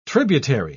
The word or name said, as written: tributary